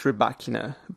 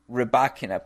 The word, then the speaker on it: Rybakina: But if I'm not mistaken, it's pronounced Rybakina. – Rybakina, perfect. Rybakina,